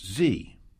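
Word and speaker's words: Xe: Blackwater has changed its name to Z, spelled X-E, pronounced Z.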